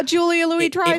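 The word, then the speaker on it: Julia Louis-Dreyfus: It might also be the schedule for, I don't know how to pronounce her name. Louise Dreyfus, however you say it. – Yeah, Julia Louis Dreyfus.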